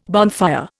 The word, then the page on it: bonfire: Indian